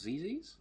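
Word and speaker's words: Zizzi: Zizi or whatever on a Saturday. There is just a kind of... – How did you pronounce Zizi's? – Zizi. – Okay, bold. I mean, my Italian, as we all know, is ciao.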